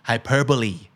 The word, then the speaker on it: hyperbole: อ่านว่า hyperbole ไม่ใช่ hyperbo นะครับ hyperbole hyperbole